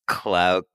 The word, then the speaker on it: Khlow: Okay, yeah, so in the first one that we got, it's from the strongest Clo fanatic. – And it's spelled KHLOW, which is like a mix between Chloe and Glow, Glow, Glow.